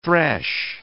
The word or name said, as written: thresh